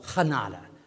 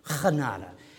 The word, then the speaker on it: genade: But in Dutch, it's called genade, genade. Okay, I had to learn how to say that correctly in order for him to let go.